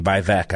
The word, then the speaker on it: Vivek: Vivek, I think, is how it's pronounced.